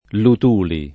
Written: Luthuli

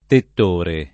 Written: tettore

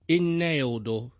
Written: enneodo

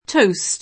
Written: toast

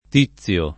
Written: Tizio

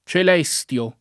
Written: Celestio

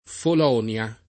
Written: Folonia

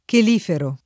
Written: chelifero

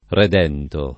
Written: redento

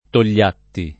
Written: Togliatti

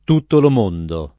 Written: Tuttolomondo

